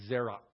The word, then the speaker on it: zera: And if you're going to properly pronounce the Hebrew there, you have to actually close your throat at the end of that, because the closing consonant there is a hard closing. So it's Zera.